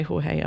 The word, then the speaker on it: Jorge: It looks like George. – Yeah. – Sorry, Jorge. produced and mixed by Jorge Albrecht.